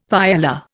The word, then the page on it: paella: Indian